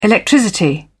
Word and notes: electricity: British English

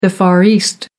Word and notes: far east: American English

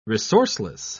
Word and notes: resourceless: British English

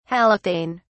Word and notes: halothane: British English

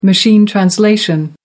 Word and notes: machine translation: American English

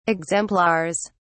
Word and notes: exemplars: British English

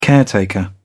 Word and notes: caretaker: British English

